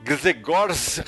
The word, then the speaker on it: Grzegorz: Grzegorz Rosinski. É que se escreve G-R-Z-E-G-O-R-Z. – É, Gregor Rosinski.